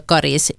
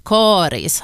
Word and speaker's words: Karis: Rekommendationen om långt a karis har funnits sedan början av 1960-talet, och för det så fanns det faktiskt inte uttalsrekommendationer överhuvudtaget. Samtidigt är uttalet med korta troligen äldre, ofta är det talspråkliga uttalet äldre än det officiella.